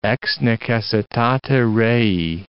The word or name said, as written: ex necessitate rei